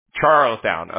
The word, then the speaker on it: Charlestown: And then, Tallstown? Am I saying that right? – Charlestown? – Charlestown. Okay. Yeah. Yeah, I thought I had it in there wrong.